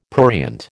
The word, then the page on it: prurient: American English